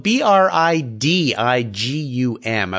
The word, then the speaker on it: Bridigum: That's no, it's, it's, when you look at it, you don't want to say it the way it's spelled. B R I D I G U M, a Bridigum.